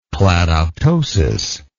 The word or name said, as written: cladoptosis